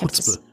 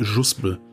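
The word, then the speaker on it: Chuzpe: Chutzpe. Ja, also Chutzpe. aber garantiert nicht Juspe.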